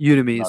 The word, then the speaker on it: Udemy: Is it pronounced Udemy or Udemy? – Udemy is fine.